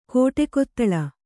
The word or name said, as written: ಕಾಡುಕಿತ್ತಲೆ